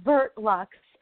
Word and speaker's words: Vert Lux: Verite Lux, is that correct? – It's actually Vert Lux, and it's French for green luxury.